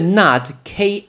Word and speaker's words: knot: 那个 nut，k n o t，k 不发音，所以 nut 也是打结，所以绑个包头叫做 bun 或是 nut。